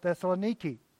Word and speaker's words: Thessaloniki: And I think it's characteristic of what's going on in Thessalonica, or as they call it, Thessaloniki. That's how they pronounce it today.